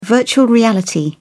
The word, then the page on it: virtual reality: British English